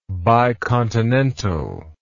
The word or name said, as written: bicontinental